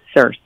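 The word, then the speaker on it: Sirsy: But it's so funny how people pronounce it now. They pronounce it in all kinds of crazy ways. But it is Circe.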